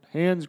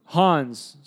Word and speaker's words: Hans: You said Hans Gruber. – Hans. – Thank you. – Sorry, Hans.